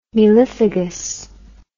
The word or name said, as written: melliphagous